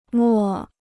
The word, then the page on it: 末: mò